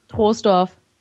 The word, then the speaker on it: Troisdorf: Troisdorf. – Du hast es genau richtig gesagt. Aber einige sagen auch schon Troisdorf, ja.